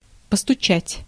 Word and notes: постучать: /pəstʊˈt͡ɕætʲ/; postutšat

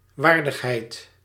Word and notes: dignité: [di.ɲi.te]; France

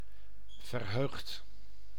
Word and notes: счастливый: /ɕːɪˈslʲivɨj/

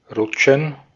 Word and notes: Rutschen: /ˈʀʊtʃn̩/; /ˈʀʊtʃən/

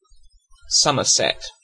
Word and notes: Somerset: British English; /ˈsʌm.ə(ɹ).sɛt/

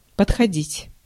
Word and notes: подходить: /pətxɐˈdʲitʲ/

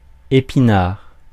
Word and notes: épinard: /e.pi.naʁ/

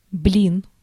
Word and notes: weight: /weɪt/

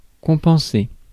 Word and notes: compenser: France; /kɔ̃.pɑ̃.se/

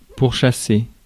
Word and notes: chase: /tʃeɪs/

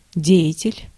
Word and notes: деятель: /ˈdʲe(j)ɪtʲɪlʲ/; dejatel

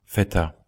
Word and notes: feta: /fɛ.ta/; /fe.ta/

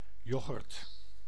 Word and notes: yoghurt: Dutch

